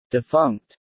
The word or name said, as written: Defunct